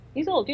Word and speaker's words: Isolde: But more about, I can never pronounce this name properly. Isolde? – Isolde, you know, Tristan and Isolde, Arthurian legend. – That's where I was going with the name. I just never pronounce it properly.